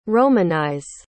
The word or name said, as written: romanize